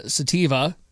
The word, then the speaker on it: Sativa: The couple named their first daughter, who was born in October in 2018, Sativa. Am I saying that right here, potheads? – Yeah.